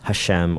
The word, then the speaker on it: Hashem: Instead, they either referred to it as simply the name, the Hebrew word for which is Hashem, or it's spelled H-A-S-H-E-M, or they used another name for God instead, and that's Adonai, which is spelled A-D-O-N-A-I.